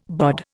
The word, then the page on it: BOD: Indian